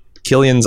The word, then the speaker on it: Cillian: I'm being told that's a hard C sound, Cillian Murphy, but I'm also being told that by Timo, who's a noted liar. – True. – So I'm going to say Cillian. Cillian's Irish red.